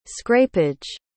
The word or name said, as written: scrapeage